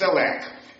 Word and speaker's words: CELAC: So they formed an organization whose Spanish acronym is pronounced as CELAC.